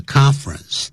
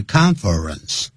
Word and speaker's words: conference: 这个研讨会呢大会呢都叫 conference。你看它本来是三个音节 conference, 可是我怎么念的是两个音节 conference? 因为 conference 比较好发音所以外国人就是念 conference, 而不是念 conference。